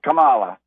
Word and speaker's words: Kamala: Yes, Kamala.